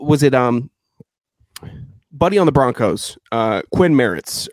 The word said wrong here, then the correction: Meinerz: or Mer- Qu- Quinn Meinerz. Yeah, yeah, yeah. He, uh, way to go, with that pronunciation. That was not even close.